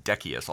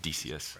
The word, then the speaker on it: Decius: It's actually pronounced Decius, although I always pronounced it Decius.